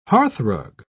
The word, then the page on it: hearthrug: British English